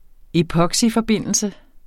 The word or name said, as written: epoxyforbindelse